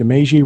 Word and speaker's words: Meiji: And somewhere down his first post, he gets into, since the Meiji Restoration, that's how you pronounce it, I think, the Meiji Restoration? – Right. I think that's right.